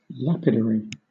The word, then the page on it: washout: British English